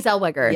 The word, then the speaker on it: Zellweger: Zellweger? Is that how you say it? – Yes, Renee Zellweger.